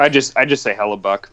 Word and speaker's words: Hellebuyck: I just say Hellebuyck. – Yeah, Hellebuyck. – I don't even try with the Y. – Yeah, but just ignore that Y. The Y is silent.